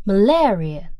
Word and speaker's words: malaria: 大家注意一下这个单词的读音，念作 malaria, 重音在 la 上面。